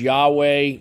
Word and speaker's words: Yahweh: And especially the Tetragrammaton, which most people pronounce Yahweh because there's no vowels in Hebrew, and because the Hebrew letter for the Y is halfway between a Y and a J sound.